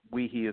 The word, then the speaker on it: Wiehe: And just remember that Wiehe is spelled W-I-E-H-E. – Yeah, you don't want to spell it W-E-E.